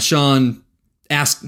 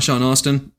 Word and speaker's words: Astin: So he and Sean Astin. It's not Sean Austin. You know, it's spelt Austin. – I think it's Astin. There's no U.